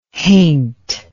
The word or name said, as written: haint